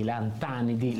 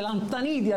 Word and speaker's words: lantanidi: lantanidi. L'accento sulla A, non dire lantanidi, altrimenti non puoi fare divulgazione scientifica.